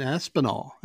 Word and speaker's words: Aspinall: I am on the DL here with Brian Aspinall, and I'm saying that correctly, right? – That's it, yep.